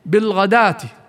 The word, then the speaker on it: بالغداة: ومثلها بالضبط كلمة بالغداه في قوله سبحانه وتعالى بالغداة والعشي، فالواو فيها وإن كانت مكتوبة في المصحف إلا أنها غير منطوقة بالغداة،